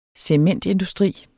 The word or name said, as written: cementindustri